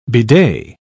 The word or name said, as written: bidet